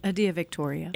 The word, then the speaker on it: Adia Victoria: How do you say her name? – Adia Victoria.